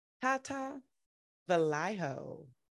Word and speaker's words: Tata Velayo: With that said, I get to bring to the Try Beginner's Luck family, get to bring to the stage, Tata, Velayo.